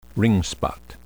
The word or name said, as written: ringspot